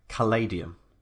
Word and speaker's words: Caladium: I had to confirm this, the pronunciation with him because I wasn't sure if it was Caladium or Caladium. But uh, he said, yeah, no, Palladium to rhyme with Palladium.